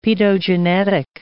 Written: paedogenetic

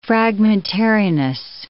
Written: fragmentariness

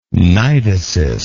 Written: cnidocyst

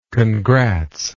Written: congrats

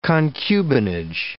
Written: concubinage

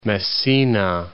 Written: Messina

